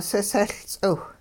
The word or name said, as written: sisehL-ts'iw